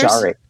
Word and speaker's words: Koshare: I'm going to pronounce this probably wrong. Kosher Indian dancers? Koshare. Koshare. Koshare, of course. – That sounds much more accurate.